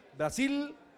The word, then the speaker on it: Brasil: Brasil no se dice Brasil. Brasil con L al final, se pronuncia la L, Brasil, ok, Brasil.